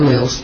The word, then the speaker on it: alleles: Alleles, yes.